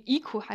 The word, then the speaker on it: Ico: Und wenn man die Spiele vom Team Ico, nee, Ico heißt es tatsächlich, ich habe nämlich auch extra die Aussprache nochmal nachgeguckt, Ico ist es.